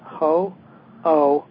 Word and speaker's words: Ho'oponopono: Yeah, it's actually pronounced, Susie, if I may interrupt you real quickly, it's Ho'oponopono.